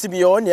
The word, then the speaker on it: Simeone: Then Unai, Simone or Simon, I don't know how to say it. – That's Simeone, yeah.